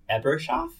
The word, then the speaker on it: Ebershoff: Another one is The Danish Girl by David Ebershoff, is that how you pronounce that? – I believe that is correct.